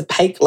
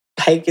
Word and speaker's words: Paech: Yeah, so I'm a pretty big Twitter user still, so you can find me at my at my full name, Vanessa Paech, like cake. Vanessa with an E. Paech is P A E C H. I have to spell it because it looks like peach, but it's not.